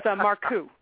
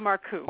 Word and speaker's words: Marcou: You got the first syllable. It's Marcou, Paula Marcou.